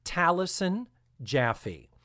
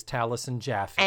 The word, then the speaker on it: Taliesin Jaffe: It is pronounced, as far as my research tells me, it is pronounced Taliesin Jaffe. It is T-A-L-I-E-S-I-N. – The actor, yes. – J-A-F-F-E. Yes, the actor is Taliesin Jaffe.